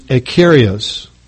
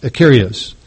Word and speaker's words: akeraios: The Greek word translated harmless in Matthew 16:10 is akarios. It's spelled A-K-E-R-A-I-O-S, akarios.